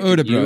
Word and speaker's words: Örebro: Am I saying that right? Örebro? – Örebro, maybe, with the like Örebro. There we go.